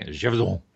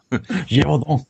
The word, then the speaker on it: Gévaudan: The Beast of Gévaudan, which also, I don't know how to pronounce that. – Gévaudan. – Gévaudan.